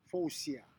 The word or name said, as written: fousia